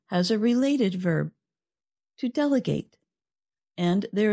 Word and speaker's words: delegate: So the noun delegate has stress on the first syllable, del. – And the verb form, delegate, has what we call secondary stress on the last syllable. It's not quite as strong as the first syllable, but it is enough to make the vowel sound change.